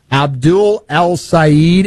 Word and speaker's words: El-Sayed: He's a Democrat, Abdul El-Sayed. Am I pronouncing his name right? – You got it.